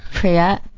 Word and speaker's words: Priya: It's Priya Anjali Rai. – How do you spell Priya? – Rai. – It's Priya, P-R-I-Y-A. Mm-hmm. Anjali is spelled A-N-J-A-L-I, last name Rai, R-A-I.